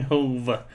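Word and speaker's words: Hov: How was Yeezy and Hawes? Am I saying that right? – Yeezy and Hove.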